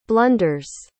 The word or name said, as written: blunders